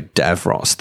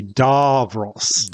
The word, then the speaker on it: Davros: Davros, the way that he shouts as well. – Mm. I'm sorry, I've been mispronouncing that. It should be Davros.